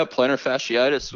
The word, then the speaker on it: plantar fasciitis: Yeah, plantar fasciitis.